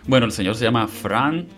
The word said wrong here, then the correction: Franz: Franz. – Franz.